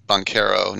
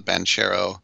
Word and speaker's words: Banchero: Jeremy Roach, Mark Williams, they help out those freshman phenoms like AJ Griffin and Paolo Banchero. I realized that he pronounces it the Italian way, Banchero, not Banchero.